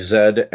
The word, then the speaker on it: Z: That was the place I learned that there were these strange people that lived to the north of us who pronounced Z like Zed, and they had a red flag with a maple leaf on it.